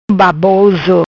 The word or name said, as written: Baboso